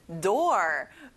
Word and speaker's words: door: 그 다음은 door, 문이에요. 친구들, 혹시 floor라는 단어 기억나나요? o가 두 개인데 oo 소리가 안 나고 or 소리를 내야 돼요.